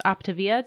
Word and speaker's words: Optavia: So the company is Optavia. Did I pronounce it right? – Mm-hmm.